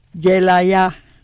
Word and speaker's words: Yelaiah: Yelaya, Y-E-L-A-I-A-H, Yelaya.